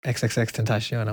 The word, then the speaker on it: XXXTentacion: And he's like, XXXTentacion.